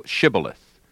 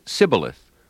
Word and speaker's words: shibboleth: If he said, "Nay," then they said unto him, "Say now, shibboleth." And he said, "Sibboleth," for he could not pronounce it right.